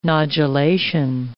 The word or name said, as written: nodulation